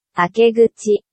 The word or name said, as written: akeguchi